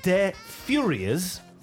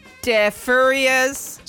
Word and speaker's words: Deux Furieuses: Because phonetically, they spelled it D-E-F-U-R-Y-E-Z. So I thought, oh, is it Deux Furieuses? Or could it be Deux Furieuses. – So it depends if you want the F-U-R-Y to be Fury, as in Billy Fury, or indeed something that's a bit hirsute.